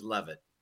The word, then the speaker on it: Leavitt: The original pronunciation was Leavitt but over the years, the English translation is love it.